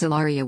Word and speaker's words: Hilaria: That is Hilaria with a silent Spanish H.